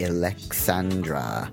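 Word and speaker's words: Illexxandra: Mixtape 118 is Vitamin B Summer Sessions by DJ Alexandra. I guess that's how you say it. It's I-L-L-E-X-X-A-N-D-R-A.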